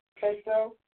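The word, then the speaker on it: Perthro: It's spelled P-E-R-D-H-R-O. It's actually pronounced P-E-R-T-H-R-O-W. – Oh. – Okay. – Prethro.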